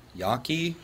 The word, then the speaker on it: Yaqui: Because the brothers were, I don't know how to pronounce this, Y-A-Q-U-I, Yaqui? – Yeah, Yaqui probably.